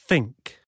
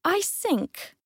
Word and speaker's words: think: Jeżeli nieprawidłowo nauczycie się wymawiać dźwięk th, niechcący zmienicie sens zdania. Zatem jeżeli Państwo wymówicie I think, to będzie znaczyło, że toniecie. Wyraz sink znaczy tonąć. A think myśleć. Dlatego zamiast tego, aby z mądrym wyrazem na twarzy powiedzieć I think, ja myślę, możecie powiedzieć I sink, ja tonę.